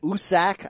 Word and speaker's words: Usyk: Oh, hey, Usyk. You know Tyson Fury pronounces it Usak? I love that.